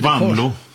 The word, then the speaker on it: Wanlo: Ja, det är lite genant då eftersom du är gammal trotjänare och jag blir aldrig riktigt klok på hur du helst uttalar ditt namn. – Wanlo. – Det är med kort då.